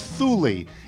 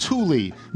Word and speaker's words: Thule: When I heard you pronounce the choice of all Subaru owners for roof cargo as Thule, it is obviously pronounced Thule, Neanderthals, owner of 3 Subarus and a Chevy Volt in Park City, Utah.